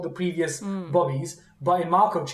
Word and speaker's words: Markov: Markov. M-A-R-K-O-V. Markov chains.